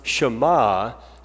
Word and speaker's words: Shema: Here in the Hebrew, that Hebrew word is pronounced Shema.